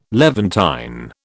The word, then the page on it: Levantine: British English